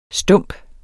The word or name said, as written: stump